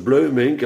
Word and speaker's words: Bloemink: En dan heb je Bloemink, dat spreken ze uit als Bleumink.